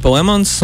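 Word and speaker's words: Plemons: Galvenajā jomā ir Benedikts Kamberbačs, viņu asistē Jessijs Palemans. – Un... Komplimenti par viņa uzvārdu izrunāšanu.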